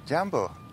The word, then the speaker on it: Jambo: Jambo.